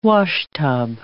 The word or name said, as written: washtub